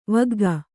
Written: ವಗ್ಗ

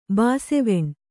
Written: ಬಾಸೆವೆಣ್

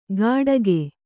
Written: ಗಡಗೆ

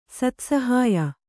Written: ಸತ್ಸಹಾಯ

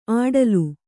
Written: ಅಡಲು

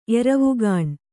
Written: ಎರವುಗಾಣ್

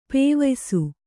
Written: ಪೇವಯ್ಸು

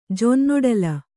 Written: ಜೊನ್ನೊಡಲ

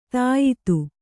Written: ಧೀಯುತ

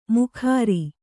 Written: ಮುಖಾರಿ

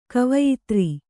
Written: ಕವಯಿತ್ರಿ

